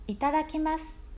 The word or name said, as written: itadakimasu